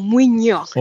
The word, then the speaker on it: Mwinjo: It's a soft nyo, Mwinjo, like a Y Mwinjo.